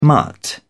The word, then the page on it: mutt: British English